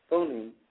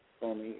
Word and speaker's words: Funnie: Rabbi Funi, if I'm pronouncing his name, Funi, F-U-N-N-I-E, he has the largest synagogue or the largest church in probably the world of Hebrew Israelites, right there in Chicago.